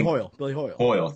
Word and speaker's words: Hoyle: Woody Harrelson's character, Billy, I can't pronounce his last name, but Billy Hoyle.